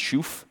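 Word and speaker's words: shuf (שוף): The Hebrew word that we translate into bruise here is pronounced shuf.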